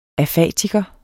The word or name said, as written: afatiker